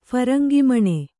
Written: ಫರಂಗಿಮಣೆ

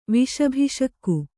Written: ವಿಷಭಿಷಕ್ಕು

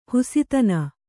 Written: ಹಸುತನ

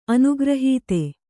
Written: ಅನುಗ್ರಹೀತ

